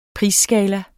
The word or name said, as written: prisskala